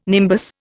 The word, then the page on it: nimbus: Indian